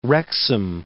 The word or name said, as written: Wrexham